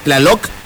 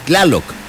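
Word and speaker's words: Tláloc: Pero no es Tlaloc, es Tlaloc, el dios de la lluvia.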